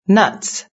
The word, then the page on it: nuts: British English